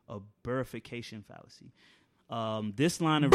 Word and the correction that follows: bifurcation: Actually, I think I said it wrong. – Oh, my bifurcation. Bifurcation.